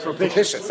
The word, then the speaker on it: propitious: Propetitious means time, and the time is Masugal. – Propitious, sorry.